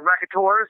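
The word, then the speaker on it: Raconteurs: the Racutioners or Racut, I can't pronounce that shit, dude. But Jack White's second band too. Yeah, Racutors. Yeah, that's how you have to pronounce it.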